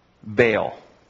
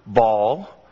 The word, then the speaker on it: Baal: Or, depending on how you pronounce the word, Baal. Or, if you go right back to the original Hebrew, you would pronounce it, and I'm only going to do it once today because it almost hurts, Baal. Because in Hebrew, every consonant has a vowel, and so when you have 2 vowels together, you split up the syllables.